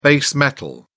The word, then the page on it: base metal: British English